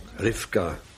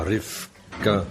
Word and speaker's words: Rivka: Rivka moet je tegenwoordig zeggen. Rivka. Hè? Ja, dat is een Jiddische uitspraak. Rebecca, Rivka, de bezen.